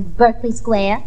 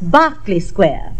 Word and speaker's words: Berkeley Square: But my family had a perfectly top-hole tizzy when I left our home in Berkeley Square. – Now, as one nightingale to another, that is pronounced Berkeley Square.